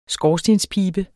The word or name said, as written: skorstenspibe